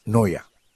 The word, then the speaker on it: Neuer: En fin, pero al final ellos me decían con mucha risa, me decían, no, se pronuncia Noia.